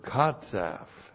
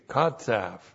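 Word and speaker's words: qatsaph: And then the verb katsaf. That's Q-A-T-S-A-P-H, katsaf.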